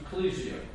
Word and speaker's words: ekklesia: It comes from the Greek word, and the Greek word is pronounced ecclesia, E-K-K-L-E-S-I-A.